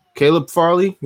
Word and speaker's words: Caleb Farley: Kelby Farley, Caleb Farley. I'm not sure if I'm pronouncing that right. – Yeah, Caleb. – Caleb Farley. Yep, cornerback.